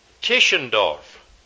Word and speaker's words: Tischendorf: The answer is Dr. Constantine, C-O-N-S-T-A-N-T-I-N, Tischendorf. T-I-S-C-H-E-N-D-O-R-F, a German biblical professor and scholar. And you need all that down. It's not going to be hard. Dr. Constantine Tischendorf. It's spelled almost like it's pronounced. T-I-S-C-H-E-N-D-O-R-F.